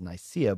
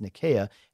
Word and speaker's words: Nicaea: That's actually how it's pronounced. It's often referred to as Nicaea, but it's pronounced Nicaea, and it's near Constantinople.